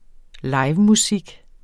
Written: livemusik

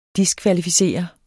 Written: diskvalificere

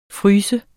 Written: fryser

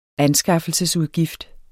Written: anskaffelsesudgift

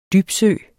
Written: dybsø

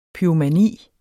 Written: pyromani

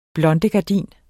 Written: blondegardin

